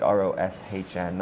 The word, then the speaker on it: ROSHN: And they're actually the sponsor of the Russian Saudi Pro League, ROSHN, not RUSSIAN.